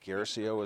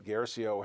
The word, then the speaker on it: Guercio: I think Guercio is how it's pronounced, who did um, Electric Glide in Blue, which is a movie that is available today.